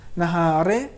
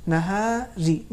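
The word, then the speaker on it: النهار: نہاری نہارے صحیح نہیں ہے، یہ اردو والا تلفظ ہو جائے گا، لیکن عربی میں ترقیق کے ساتھ اگر ہم ادا کریں گے تو ہوگا نہاری، بالکل باریک و نازک، جسے ترقیق کہا جاتا ہے.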